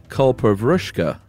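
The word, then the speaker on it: kalpavriksha: First of all, it's not pronounced that way. It is pronounced Kulpa Vrishka.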